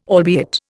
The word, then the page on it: albeit: Indian